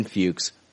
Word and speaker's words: Fuchs: Patreon supporter, Brian Fuchs, rhymes with pukes.